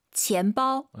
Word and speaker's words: 钱包: 자, 지갑 발음하실 때 지엠바오 너무 잘하셨어요. 근데 조금 힘을 빼시면 어떨까요? 한번 들어볼게요. – 지엠바오. – 네, 지엠바오. 약간 여러분들께서 쌍비읍이 너무 강해서 지엠바오 이렇게 하는 것보다는 약간 선생님, 쌍비읍과 비읍의 중간은 지엠바오 어때요?